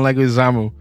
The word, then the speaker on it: Leguizamo: John Leguizamo.